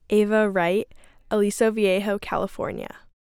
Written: Ava Wright

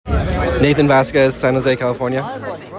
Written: Nathan Vasquez

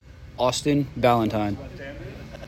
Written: Austin Balentine

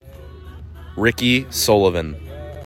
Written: Ricky Sullivan

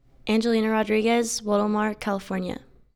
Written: Angelina Rodriguez

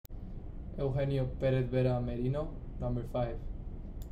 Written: Eugenio Perez-Vera Merino

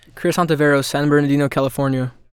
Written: Chris Ontiveros Jr.

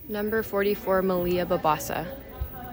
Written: Malia Babasa

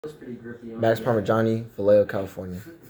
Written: Max Parmigiani

